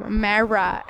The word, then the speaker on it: Marra: My name is Kim, K-I-M, Marra, M-A-R-R-A, and this is...